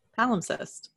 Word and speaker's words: palimpsest: P-A-L-I-M-P-S-E-S-T. Palimpsest. – P-A-L-I-M-P-S-E-S-T. Mm-hmm. So there's an imp in the middle. – Yes. – And the cest at the end.